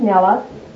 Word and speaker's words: Nella: So they knew when I say my name, I'd say, my name is Nella, N-E-L-L-A.